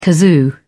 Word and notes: kazoo: British English

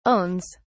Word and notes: owns: British English

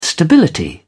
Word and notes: stability: British English

